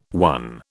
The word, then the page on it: one: British English